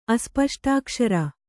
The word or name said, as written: ಅಸ್ಪಷ್ಟಾಕ್ಷರ